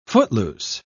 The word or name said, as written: footloose